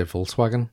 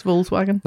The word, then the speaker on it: Volkswagen: what's with the silent K in Volkswagen? And I will say it right that time. Is this Armaian dialect? Now, Why, what way do you normally say it? – Apparently I say Volkswagen. – He does. – Volts? – Just yeah. V-O-L-S, Volkswagen.